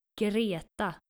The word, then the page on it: Greta: skandinavisch; female